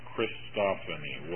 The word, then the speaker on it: Christophany: Christophany. Water. It's supposed to say four syllables.